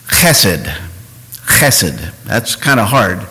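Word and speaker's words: chesed (חסד): Look at that word, chesed. It's a Hebrew word. There's a little soft guttural sound in the back of the throat. Phonetically, you might say chesed, like cheese or chase.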